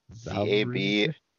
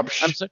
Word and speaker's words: Zabrze: Zabrze. – Zabrze. Yeah. Zabrze.